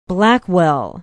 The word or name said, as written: blackwell